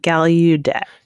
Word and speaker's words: Gallaudet: Gallaudet is what I seem to remember this being pronounced as, but I think he said something more like Gallaudet. – He did put a bit of a y in there.